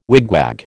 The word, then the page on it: wigwag: American English